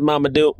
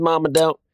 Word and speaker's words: Mamoudou: It stars uh, Mamoudou Athie. Is that how you pronounce his last name? Yep, that's Mamoudou. That's Mamoudou.